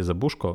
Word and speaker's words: Забужко: і скажу, що правильний наголос все ж таки на букву У, на літеру У в прізвищі Забужко.